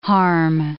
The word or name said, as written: harm